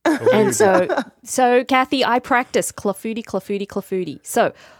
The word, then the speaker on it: clafoutis: Now, your next recipe is for a plum clafoutis. – Clafoutis. – You said it right. Yes.